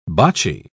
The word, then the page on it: boccie: British English